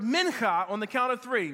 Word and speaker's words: mincha: The word is mincha. Mincha, M-I-N-C-H-A, mincha.